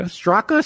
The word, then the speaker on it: Straka: Straka.